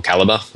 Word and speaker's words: Calibre: called Calibre um, or Caliber, which probably is probably actually how it's spelled, it's pronounced.